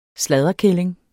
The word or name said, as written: sladderkælling